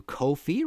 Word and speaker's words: Ko-fi: If you like this show, you want to support future episodes, please go to Ko-fi, rhymes with no fee, .com/MartinPopoff.